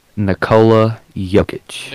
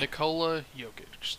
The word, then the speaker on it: Nikola Jokic: Nikola Jokic. – Nikola Jokic, thank you.